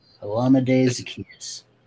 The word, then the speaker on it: Olamide Zaccheaus: Olamide Zaccheaus. – Olamide Zaccheaus, thank you.